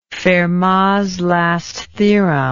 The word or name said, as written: fermats last theorem